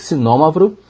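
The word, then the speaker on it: Xinomavro: Outras uvas muito interessantes que eu gostei de lá são a Cinômavro, com X, que se Cinômavro, né? Mas se pronuncia Cinômavro, que é uma uva tinta muito potente, lembra muito a Nebbiolo do Barolo.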